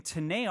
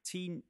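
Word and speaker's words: Tenet: We're doing Tene, aren't we? – Yeah, we are doing Tene. – Tene. – That's how you say it, right? – That's it. Of course we're going to cinema. My mistake. Tine? Tine? Tine? – Tene? – Mixed reviews, I've heard.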